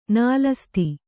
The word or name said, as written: ನಾಲಸ್ತಿ